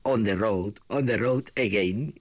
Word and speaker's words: On the Road Again: In the Road Ain, que ahora Norberto me corregirá en la pronunciación. No, bien, on the road, ¿qué he dicho pues? On the road again.